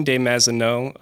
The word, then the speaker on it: de Mazenod: Eugene de Mazenod, A Saint for Today is the name of the book. – De Mazenod. No D. Don't pronounce the D on the end. – Yeah, it's French.